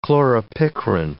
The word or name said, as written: chloropicrin